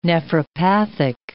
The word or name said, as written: nephropathic